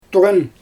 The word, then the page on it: tuɣan: Xyzyl